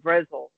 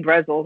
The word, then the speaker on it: Brezel: She's like, no, it's pronounced Brezel. It's I'm like, all right, so I said something like, oh, I'm gonna get the pretzel. She's like, you mean Brezel?